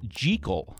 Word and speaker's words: Jekyll: So apparently, Robert Louis Stevenson was very specific that it should actually be pronounced Jekyll, not Jekyll. He said J as if it were spelled J-E-E-K-I-L-L, Jekyll.